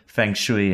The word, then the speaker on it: feng shui: There's really good feng shui in this room, um, looking out over downtown Toronto. I said it right. – Yeah, you did.